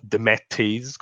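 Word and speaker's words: DeMatteis: Sì, allora io devo dire che Jean-Marc De Matteis o De Matteis, come dicono gli americani quando, quando lo pronunciano,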